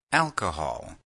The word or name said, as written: alcohol